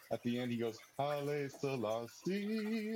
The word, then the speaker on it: Haile Selassie: Haile Selassie. Is that how you say his name? – Haile Selassie.